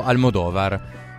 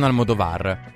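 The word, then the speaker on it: Almodóvar: Ah, chiedo scusa per la pronuncia del regista, che è appunto Almodovar e non Almodovar.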